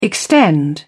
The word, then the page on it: extend: British English